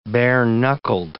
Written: bare-knuckled

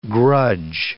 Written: grudge

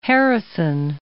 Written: Harrison

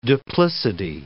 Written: duplicity

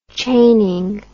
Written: chaining

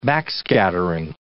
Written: backscattering

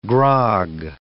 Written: grog

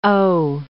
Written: haut

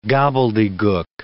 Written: gobbledygook

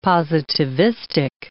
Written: positivistic

